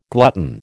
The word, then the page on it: glutton: American English